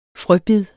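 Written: FRØBID

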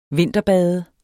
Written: vinterbade

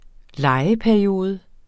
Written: lejeperiode

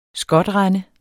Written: skotrende

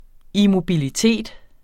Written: immobilitet

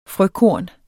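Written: FRØKORNET

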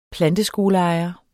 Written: planteskoleejer

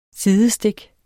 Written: sidestik

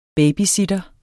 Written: BABYSITTER

